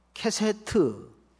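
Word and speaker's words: 케세트 (קשת): 이 무지개라는 언어가 굉장히 재밌는데 한번 잊어버려도 좋으니까 따라서 하십시다. 케세트. 발음 주의하세요. 히브리 말로 케세트라는 말이에요.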